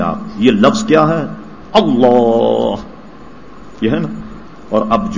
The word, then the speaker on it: اللہ: اس کا صحیح تلفظ کیا ہے؟ اللہ. ساکن کیا جائے ہے پر.